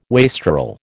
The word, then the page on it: wastrel: American English